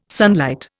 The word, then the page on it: sunlight: Indian